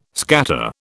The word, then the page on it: scatter: British English